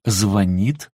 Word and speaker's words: звонит: Но, пожалуй, на первом, а в данном случае непочетном месте, стоит неправильное ударение в словах «звонит», «звонят». Того, кто ставит ударение на первом слоге, окружающие постоянно отдергивают и поправляют, иногда даже добавляют «не позорься».